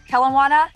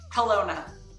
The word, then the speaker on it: Kelowna: And I am super stoked today because I have the one and the only from Kelowna. How do you pronounce it? – Kelowna. – Kelowna. See, I had to just jack it up and make it so different.